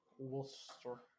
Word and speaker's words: Worcester: Worcester? – Worcester. – Ooh, I had to learn that one. When I saw the name of the place, I was like... Worcester. – Yeah, I was like, Worcester.